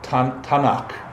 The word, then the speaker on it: Tanakh: They don't call it the Bible, they call it the Tanakh. Right? Am I saying that right, Diane? Tanakh? – Sounds good to me.